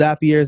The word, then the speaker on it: Zapier: It's pronounced Zapier. Zapier makes you happier, um, and it it connects apps.